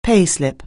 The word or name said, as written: payslip